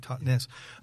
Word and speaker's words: Totnes: Yeah, it's the Totnes, as you say it properly, Totnes.